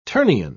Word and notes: ternion: British English

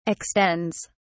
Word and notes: extends: British English

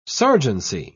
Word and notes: sergeancy: British English

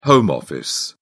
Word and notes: home office: British English

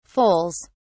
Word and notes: foals: British English

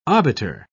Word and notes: obiter: British English

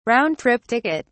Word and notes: round trip ticket: British English

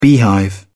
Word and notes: beehive: British English

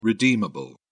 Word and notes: redeemable: British English

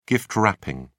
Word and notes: gift wrapping: British English

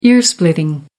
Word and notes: ear splitting: American English

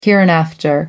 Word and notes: hereinafter: American English

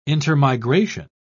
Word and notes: intermigration: British English

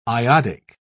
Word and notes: iodic: British English

